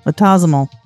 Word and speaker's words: autosomal: Autosomal.